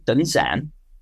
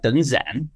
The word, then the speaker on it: Đơn Giản: But when it comes to Dun Zan, I've chosen to embrace the northern pronunciation, even though I live in the south. And so when I introduce myself to Vietnamese people and I say, Tên tôi là Dun Zan, my name is Dun Zan, they, if they heard me correctly, they'll say back to me, Dun Yan. And I'll say, no, Dun Zan.